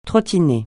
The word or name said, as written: trottiner